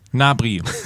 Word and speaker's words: Gnabry: Übrigens, Gnabry, weil du es Gnabry, wie spricht man? – Gnabry die Pfote. – Gnabry, so.